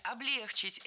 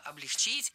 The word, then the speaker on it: облегчить: Облегчили, облегчить – это неверно. В нашем глаголе ударение всегда стоит за корнем. Облегчило, облегчить.